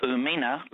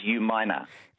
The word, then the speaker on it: Umina: And also, Umina, like you said before, is U-minor. – U-minor, that's right. But it was, I mean, had it been spelt out phonetically by Stuart, so U-minor, but people sometimes say U-mina, Umina. – Mm. – Yeah, interesting. – And it's not U, it's U. – Oh, U, sorry, U-mina, okay.